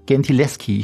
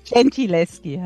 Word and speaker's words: Gentileschi: Bestes Beispiel, Sie haben vor kurzem auch erst ein Buch drüber geschrieben, die Artemisia Gentileschi. Ich hoffe, ich habe es richtig ausgesprochen. – Nicht ganz. Okay. Gentileschi halt.